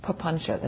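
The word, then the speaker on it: papañca: That's papuncha. That's a fabulous word. I love this word, papuncha. There's a whole talk on papuncha. You should have regular talks on papuncha. P-A-P-A-N-C-A. The C is pronounced ch. Papuncha.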